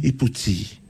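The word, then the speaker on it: ipu tī: What, how do you say that in Samoan? – Iputi.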